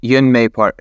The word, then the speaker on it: Yeonmi Park: I think most of us are familiar with Yomi Park from North Korea. Did I pronounce that right, by the way? Is that how you say it? – Yeonmi Park. Yeonmi Park.